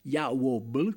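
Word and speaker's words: Jah Wobble: Sto parlando di John Joseph Ward, al secolo si chiamava così, lui è più noto come Yawobble con la pronuncia all'italiana,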